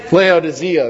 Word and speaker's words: Laodicea: For I want you to know how great a struggle I have for you and for those at Laodicea, or Laodicea, that's how I say it in Spanish. How do you say it in English? Laodicea, there it is, thank you.